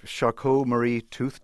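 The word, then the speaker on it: Charcot-Marie-Tooth: I've recently learned, and correct me if I'm pronouncing this name wrong, that I recently learned that you have Charcot-Marie tooth disease. – That's right, CMT.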